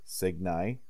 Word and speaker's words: Cygnii: C-Y-G-N-I-I is pronounced Signi. W-Y-V-R-E-N is pronounced Wyvern. T-A-R-R-A-N-T is pronounced Tarrant. G-A-N-D-O-R is pronounced Gandor. F-I-K-H is pronounced Feek.